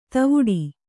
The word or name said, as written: ಧವಡ